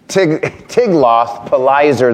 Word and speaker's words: Tiglath-Pileser: Tiglath-Pileser III.